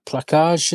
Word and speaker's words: placage: Placage, is it, or something? – That's very, very good.